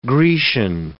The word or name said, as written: Grecian